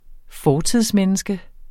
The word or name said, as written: fortidsmenneske